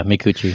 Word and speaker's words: Micucci: Danny Trejo, Ralph Garman, Kate Micucci? Is that how you say her name? – Yeah, Micucci, yeah.